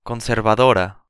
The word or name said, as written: conservadora